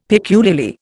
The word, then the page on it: peculiarly: Indian